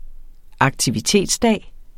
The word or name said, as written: aktivitetsdag